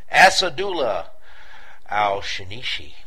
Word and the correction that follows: Asadullah al-Shishani: I'm sorry, Asadullah al-Shishani.